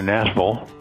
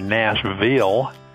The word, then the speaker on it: Nashville: Yes, we're in lovely Nashville, Tennessee, or as the locals say, Nashville. Nashville, you can't say Nashville.